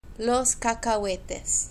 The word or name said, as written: los cacahuetes